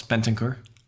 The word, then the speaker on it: Bentancur: Hopefully I'm pronouncing that close to correct. – It's close. Bentancur. – Bentancur, yeah.